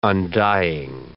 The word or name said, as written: undying